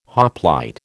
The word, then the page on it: hoplite: American English